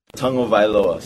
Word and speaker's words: Tagovailoa: Tagovailoa. So you say tongue first, Ovailoa.